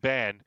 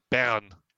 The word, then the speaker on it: bairn: Because the traditional name, it's not Ben, it's Bern. Because you've got to get that Northumbrian burr.